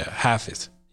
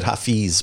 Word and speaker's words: Hafiz: And so my whole senior year, how do you say that? – Hafiz. – Hafiz? I always said hafiz, but is that hafiz? – Hafiz, yeah. – Hafiz, okay.